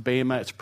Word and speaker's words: bema: It's pronounced bema. It's spelled B-E-M-A.